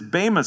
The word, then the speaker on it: bema: As the judgment seat of Christ, the Greek here is the word bema, B-E-M-A, or bema is the Greek pronunciation.